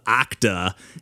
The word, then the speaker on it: OCDA: And then real quick, I did want to mention one more called Okda, and this is OCDA, but the reason I'm pretty sure it's pronounced Okda is because it's kind of all based around an octodirectional dash, as we know and love.